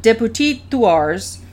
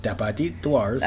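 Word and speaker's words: Dupetit-Thouars: The French captain, Deputit Thouars, protested. – You pronounced that perfectly. Deputit Thouars. – I may not have. It just sounded right. – It sounded really good.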